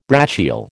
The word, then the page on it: brachial: American English